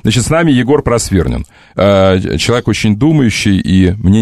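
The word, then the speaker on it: Просвирнин: Егор, извините, научите меня, пожалуйста, правильно, с правильным ударением произносить вашу фамилию. – Просвернин. – Почему мне казалось, что это аристократическая фамилия Просвернин?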